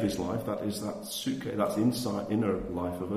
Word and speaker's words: psuche: And then there's something called the psuche, spelled P-S-U-C-H-E. It's pronounced psuche, and that's our internal life.